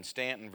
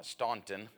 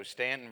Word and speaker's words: Staunton: Staunton, Virginia, and you can tell I know how to pronounce that. It's not Staunton, and so Staunton, Virginia, but what a joy to be able to be here.